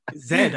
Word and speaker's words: Z: Oh, that's how they pronounce Z. I was like, oh, okay. See, I know now. Yeah. – Zed. I was yeah.